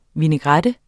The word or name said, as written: vinaigrette